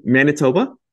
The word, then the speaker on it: Manitoba: We have Dr. Paul Marcogliese, who is currently an assistant professor at the University of, I should have asked you how to pronounce it, Manitoba? – Yes, perfect.